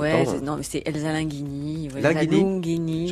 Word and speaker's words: Lunghini: Je le prononce bien, Lunghini, parce que... – Oui, à chaque fois, tout le monde me demande.